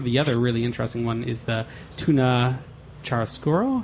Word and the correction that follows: churrasco: How do you say? – Tuna churrasco. – Churrasco, yeah. Yeah, churrasco. Churrasco is a beautiful term in our culture.